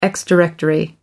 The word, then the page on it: ex directory: American English